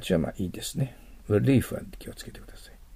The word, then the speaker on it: relief: 日本語で relief と言うけど、これは l と r の発音が違いがわからないからそう言ってるんです。実際は、 r と言わなきゃいけません。 relief です。